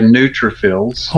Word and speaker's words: neutrophils: NEUTROPHILIS are consistently low. Is there anything I can do to raise this? What? How do you pronounce that word? – Yeah, neutrophils.